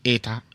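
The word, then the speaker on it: 穢多: 就是古代的这个社会阶级是很不流动的，压迫性是很大的，而且呢这个会多跟非人他们会互相包含，会多就是念成 eta eta，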